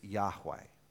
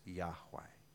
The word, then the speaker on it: Yahweh: Probably the way to pronounce it is Yahweh. The Jehovah's Witnesses pronounce it as Jehovah, which is actually the same consonants, but most likely it's Yahweh.